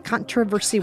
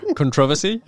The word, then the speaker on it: controversy: Can you say controversy one more time? Because it's such a fun thing for Americans to hear. – Controversy? – Controversy. – Well, because that's not how you pronounce it, you mean? Or because it's... – We say controversy, but I love hearing you say that.